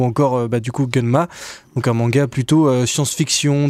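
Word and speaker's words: Gunnm: Gunmu. – Alors ça se prononce Gunma.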